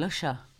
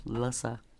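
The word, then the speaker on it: Lhasa: İspanyolca şey e, Laşa diye mi okunur? Lasa.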